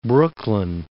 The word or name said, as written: Brooklyn